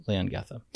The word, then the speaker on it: Leongatha: It's called Leongatha. I hope I'm pronouncing that correct, correctly. – Leongatha.